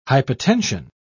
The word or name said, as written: hypotension